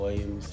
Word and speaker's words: Williams: My Facebook name, Rashard Williams. – How do you spell that? – Rashard mm-hmm. Williams, WILLIAMS.